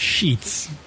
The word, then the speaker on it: Sheetz: Sheets. – That's shitty. – But it's spelled S-H-E-E-T-Z. So if you, it's like Ebonics for shits. – The one thing... – sounds like Sheets.